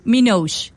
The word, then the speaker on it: Minogue: ¿Lo estoy pronunciando bien? Minogue. Kylie Minogue, ok.